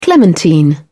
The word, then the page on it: clementine: British English